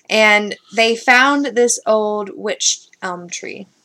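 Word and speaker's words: wych elm: A witch elm. It's spelled W-Y-C-H. – Maybe not with so much emphasis on a T, like a witch. Like yeah. Or a witch. You know? Where's my phone? W-Y-C-H? – Yeah.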